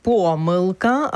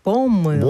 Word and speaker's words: помилка: Наше сьогоднішнє слово дня – помилка або ж помилка. – Блонд. – Ні, два Блонд. Наголоси допустимі. Це слово, яке має два допустимі наголоси.